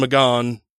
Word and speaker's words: McGahn: Anyway, the guy's name, I pronounced it as Don McGahn. – Which is funny.